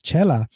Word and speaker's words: Xela: So that X in Spanish produces the ch sound. And that's why it's spelled X-E-L-A. It still says Chela.